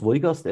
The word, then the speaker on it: Wolgast: Spricht man so aus? Wolgast, äh, Meckpom? So spricht, genau, so spricht man es aus.